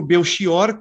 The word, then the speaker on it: Belchior: O antropônimo Belchior, comerciante que estabeleceu no Rio de Janeiro a primeira casa de compra e venda de roupas e objetos usados.